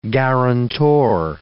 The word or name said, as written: guarantor